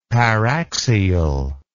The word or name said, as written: paraxial